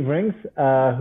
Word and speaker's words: Rienks: Was it Rienks? – Yes, sir. The E is silent. – Okay. So Steve Rienks,